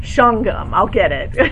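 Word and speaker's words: Shawangunk: I'll move it, only with the one correction that's pronounced Schwangum. – Schwangum. Thank you.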